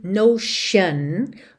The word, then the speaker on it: notion: e atenção à pronúncia de Notion, ok? Frequentemente palavras terminadas em T-I-O-N, esse T tem esse som de X ou SH, shun, shun, ok?